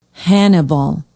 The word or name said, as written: hannibal